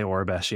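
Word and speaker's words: Aurebesh: Is that how you pronounce it? Ourobesh? – I do say Ourobesh, yeah.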